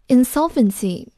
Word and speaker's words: insolvency: insolvency 名词，要注意它的发音，重音在 s 前面。